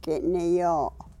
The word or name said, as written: ki ni yo